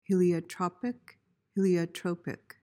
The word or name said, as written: heliotropic